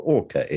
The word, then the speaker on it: ORCHA: So can you tell us what orca is? – Absolutely. Yes, it's spelled O-R-C-H-A, and it stands for the Organization for the Review of Care and Health Apps.